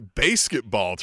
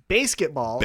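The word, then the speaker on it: BASEketball: Yeah, and for those listening. Basketball. – Basketball.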